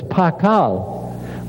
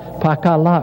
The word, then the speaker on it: Pakal: Here it is with the sound la at the end of it to say it's a shield, but it's got an L sound on the end of it. So it's Pakal with the la there. Or another way of writing it with phonetically would be to write it all phonetically. Pa, the sign pa, this fish sign is ka, and that's la, pakala or pakal.